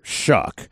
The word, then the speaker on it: Shough: And it's going to confuse people because his last name is not spelled how it's pronounced, but quarterback out of Louisville. – Tyler Shuck is so funny. He came to Manning Passing Academy in that S-H-O-U-G-H, and then they had underneath it in parentheses Shuck.